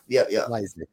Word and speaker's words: Klyczek: Klyczek? – Klyczek, yeah. – Klyczek, okay.